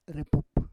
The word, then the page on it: repup: Roon